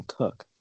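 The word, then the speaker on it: Koch: Sam Kosh, K O S E H. It's pronounced Sam Cook.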